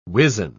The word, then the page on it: wizen: British English